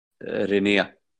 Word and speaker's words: Renia: Yeah, so like we all agreed we should just pronounce it as Swedes. Renia. – Well, it is correct in our language.